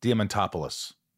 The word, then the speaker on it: Diamantopoulos: This is called Shit Talking with Chris Diamantopoulos. Did I say that right? – You said it right.